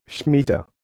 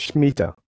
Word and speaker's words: Shmitah: and it will be Schmetter. It is spelled as S-H-M-I-T-A-H, but it is pronounced as Schmetter.